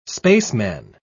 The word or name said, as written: spaceman